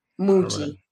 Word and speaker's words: Mooji: Yeah, it's spelled M-O-O-J-I, Muji.